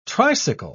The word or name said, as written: tricycle